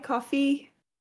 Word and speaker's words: Ko-fi: I have a store up on Ko-fi, coffee, however it is pronounced, Coffee.